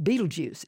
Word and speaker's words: Betelgeuse: Well, you also have a chapter when you're looking at stars, this giant red giant star, and the pronunciation, it's pronounced Betelgeuse.